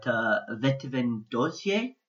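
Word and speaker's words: Vetëvendosje: I'm going to mispronounce this, I apologise, but Vetëvend Dozje Party. Quite a very good pronunciation.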